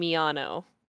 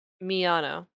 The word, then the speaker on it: Miyano: Sasaki and Miyano. – Okay. I never know how to pronounce the boy's name. I like Maya-chan. Sasaki. – Miyano.